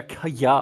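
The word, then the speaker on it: Kayah: Und zwar wurde in dem Bundesstaat Kia, also Kaya, oder ich weiß, ich glaube, spricht man eher Kaya aus, ähm, ist es vorgefallen, dass 30 Dorfbewohner getötet und verbrannt wurden.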